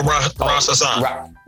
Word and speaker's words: Rasasan: Tell them how to spell Rasasan. – Rasasan. R-A-S is the first name, middle name, A-S-A-N.